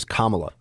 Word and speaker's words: Kamala: I say her name properly, I believe. I believe it is Kamala, is what I've been told by people who know.